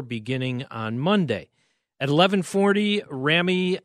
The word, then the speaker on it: Rami: So it's Rami, as in Rambo.